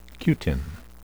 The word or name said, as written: cutin